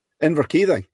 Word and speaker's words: Inverkeithing: Did I pronounce that right, Inverkeithing? – Inverkeithing, yeah. – Yeah, yeah.